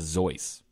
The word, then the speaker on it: Seuss: But it's a pseudonym, so I guess you can say it's Zeus. – He said it was Zeus. – Whoa. – Yeah, he said it rhymes with voice or choice.